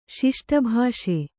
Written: ಶಿಷ್ಟಭಾಷೆ

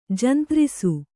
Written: ಜಂತ್ರಿಸು

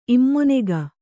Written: ಇಮ್ಮೊನೆಗ

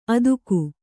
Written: ಅತ್ತಿಗೆ

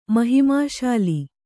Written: ಮಹಿಮಾಶಾಲಿ

